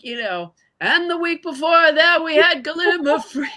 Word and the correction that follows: gallimaufry: And somebody wrote in and said, you know, that's not how it's pronounced. Oh. So all these weeks, I've thought I was educating the public in this fun way. And it turns out I was telling them something totally incorrect. Gallimaufry, which is almost impossible for me to say it the correct way.